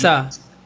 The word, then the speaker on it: JAXA: JAXA.